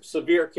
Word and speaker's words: Sevier: Sevier County or Sevier or however you pronounce it. – It's Sevier County, Your Honor.